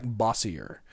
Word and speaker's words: Bossier: Bossier City is spelled like this. B-O-S-S-I-E-R. Looks like Bossier. Bossier, B-O-S-S-I-E-R, City, Louisiana, 71111.